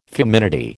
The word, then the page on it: feminity: American English